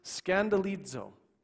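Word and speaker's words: scandalizo (σκανδαλίζω): And that word offense comes from this interesting little Greek word pronounced scandalizo. Scandalizo.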